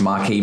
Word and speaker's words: Marquess: So it says this movie specifically is based on the case of the missing Marquess and Enola Holmes mystery by Nancy Springer. – I think it's pronounced Marquis, my dude.